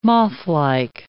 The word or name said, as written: mothlike